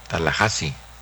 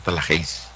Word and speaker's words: Tallahassee: Se pronuncia Tallahassee, no Tallahassee.